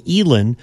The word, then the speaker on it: Eilen: Elin Jewell, that's spelled E-I-L-E-N, Elin.